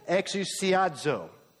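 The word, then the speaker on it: Exousiazo (ἐξουσιάζω): That word, mastered, it's a Greek word, and it's pronounced like this. See if you can say it with me. Exousiazo. The Greek word is exousiazo.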